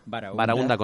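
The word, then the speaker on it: barahúnda: Barahunda con H, sí, intercalada y acento en la U.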